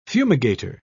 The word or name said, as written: fumigator